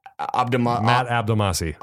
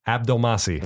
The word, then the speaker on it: Abdelmassi: Matt Abdelmassi. – Abdelmalahalu. What is it? – Abdelmassi. – That's what I said. Sure. Abdelmalahassi. – You're adding a little bit on the syllable game there.